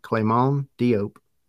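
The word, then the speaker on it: Clément Diop: I'm pronouncing that the way that it was told by Atlanta United. Clement Diop.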